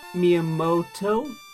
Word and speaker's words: Miyamoto: Miyamoto? Am I saying that right? – That sounds right.